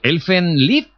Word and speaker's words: Elfen Lied: Elfen Lied. – Sí, exactamente, se pronuncia así, muy bien.